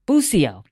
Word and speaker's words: Bucio: Bucio, did I say it right? – You did.